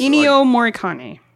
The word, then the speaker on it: Ennio Morricone: Ennio Morricone. – Or however you say it.